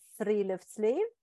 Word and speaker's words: Friluftsliv: Discover the Nordic art of Friluftsliv and embrace nature every day.